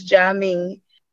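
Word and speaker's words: Juami: Yeah, I'm glad you spelled Jami, because, you know, I ain't gonna lie to you, I butchered your name when I first started. I was calling you Jawami.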